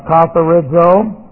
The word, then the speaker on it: katharizo: Then we have to have cleansed is an aorist active subjunctive of katharizo. So we'll take a look at this. Katharizo looks like this. K-A-T-H-A-R-I-Z long O. Katharizo.